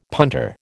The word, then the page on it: punter: American English